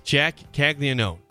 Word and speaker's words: Caglianone: Jack Caglianone.